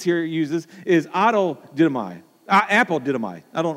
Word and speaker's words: didomai: The word give is the word dotome. If I say that right, didomai, yeah.